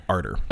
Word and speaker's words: Arter: Is it Archer or Arter? – Arter. – Okay. I thought you said Archer a second ago, and I was like, oh, I had that wrong in my notes. – No, Archer. It's spelled like Arthur, just no H.